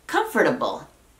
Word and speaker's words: comfortable: Yo te diría, comfortable, porque viene de la palabra comfort, que es comodidad, y able, capaz de. Entonces, comfortable no está mal dicho, pero probablemente a la hora de la hora, lo que vamos a decir es comfortable.